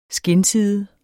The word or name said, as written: skindside